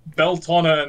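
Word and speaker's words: Bealtaine: How do you say it? – It's Beltana and Sawin.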